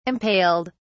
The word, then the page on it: impaled: British English